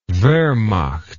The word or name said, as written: wehrmacht